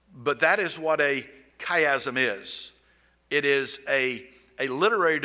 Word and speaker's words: chiasm: It looks to me like it ought to be like a chia pet, but it's not. It's chiasm.